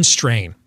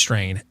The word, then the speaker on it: Strachan: Owen Strain. Is his name. – Oh, yeah. – Strachan. It doesn't look like it's pronounced strain, but it is.